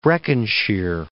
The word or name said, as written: Breconshire